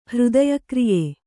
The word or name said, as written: ಹೃದಯಕ್ರಿಯೆ